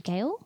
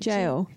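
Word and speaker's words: Gaol: And the execution was held at Melbourne Jail. How do you pronounce that? – Jail. – Jail. Great.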